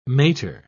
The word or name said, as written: mater